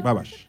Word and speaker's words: Babbage: Michael Charles Babel. ¿Babel? ¿La máquina de Babel? – Sí, Charles Babbage. – Babbage. Ok. Babbage. No sé cómo se pronuncia en realidad en ese idioma.